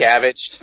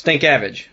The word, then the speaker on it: Stankavage: Yeah, I was going to ask you about your quarterback, Sean Stankovic. Am I saying that right? – Stankavage. – Stankavage. Okay. That's a really hard name.